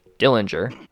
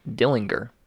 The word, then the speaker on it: Dillinger: In this case, I think it's funny because most people will pronounce John Dillinger Dillinger. However, his family would call him Dillinger.